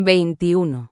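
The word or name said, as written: veintiuno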